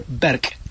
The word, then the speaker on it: Berg: But Berg, or Berg, which is would be more the German pronunciation, does mean mountain.